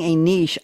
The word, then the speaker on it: niche: Creativity in business is finding a niche. I'm pronouncing it the French way, a niche, to go with Mademoiselle Boisvert, because we're in a French mode now.